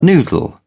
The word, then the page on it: noodle: British English